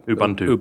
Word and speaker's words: Ubuntu: Or Ubuntu, I'm saying it correctly, right? – Yeah. – Ubuntu.